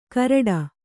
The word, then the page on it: ಘರಟ್ಟ: Kannada